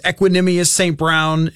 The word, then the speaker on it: Equanimeous St. Brown: I mean, some of these guys, Equanimeous St. Brown, if that's how you say it, it is how you say it.